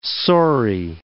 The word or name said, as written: saury